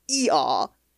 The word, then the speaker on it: Eeyore: And I didn't learn until I was, you know, like, well into my 20s that, of course, A.A. Milne was a non-rhotic speaker and he would have pronounced that donkey E-aw, like the sound a donkey makes.